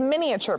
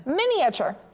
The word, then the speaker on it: miniature: It's Miniature Buddy. – Miniature? – Miniature. – That's the first time I heard someone pronounce every single letter in that word. Miniature. Anyway.